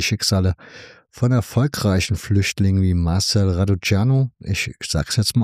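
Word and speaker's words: Marcel Răducanu: ansonsten, Raducanu hat sich, glaube ich, sehr gut angehört.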